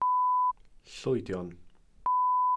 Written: llwydion

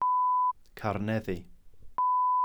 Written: carneddi

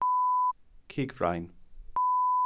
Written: cigfrain

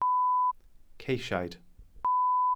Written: ceisiaid

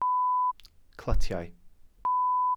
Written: clytiau

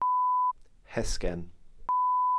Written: hesgen

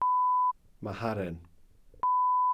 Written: maharen

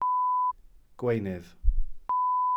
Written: gweunydd

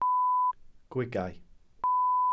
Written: gwigau

